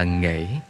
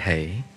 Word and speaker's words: nghệ: Có thể đọc là nghệ hay hể.